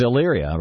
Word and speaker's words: Elyria: Elyria. How do you pronounce that? Ohio? – Very good. How are you? – It's Elyria, right? – Elyria, right.